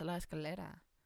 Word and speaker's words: La Escalera: You skip the A. And so I think, like, that's not how it's pronounced. There's an A. It's a La Escalera.